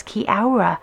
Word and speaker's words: Kia ora: It means hello in Maori, but they would tend to bring the A and the O together and pronounce it as Kiora.